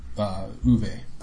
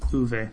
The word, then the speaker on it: Uwe: Not that it matters, but I believe it's pronounced Uwe. – Uwe.